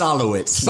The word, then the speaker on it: Solowitz: It's just like it's spelled, man. – I'm looking at it, but it does not how it's spelled. – It's Solowitz.